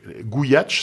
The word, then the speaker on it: Gooyatsh: Alors c'est gouyatch. ça s'écrit G-O-O-Y-A-T-S-H. Ça s'écrit pas du tout comme ça se prononce du coup.